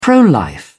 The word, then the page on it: pro-life: British English